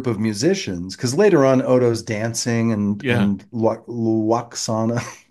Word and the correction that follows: Waxana: comes in. I can't say her name. – Waxana. – Waxana comes in.